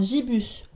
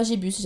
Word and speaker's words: Gibus: Les deux derniers personnages qui sont relativement souvent présentés sont Yoshi, qui est tout simplement le chef cuisinier du dirigeable, et enfin Jibus Gibus, vous prononcez comme vous voulez. Moi je préfère euh, Gibus, j'aime bien, qui est le capitaine de l'équipage.